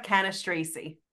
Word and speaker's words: Cannistraci: And today we have hilarious comedian Tara Canastracy.